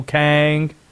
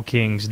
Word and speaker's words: King: that is spelled like Michael King's name. K-I-N-G. Do the people from this place in North Carolina say King, North Carolina? No, they do not. Kang. – Kang. – That's it. It's Kang, North Carolina.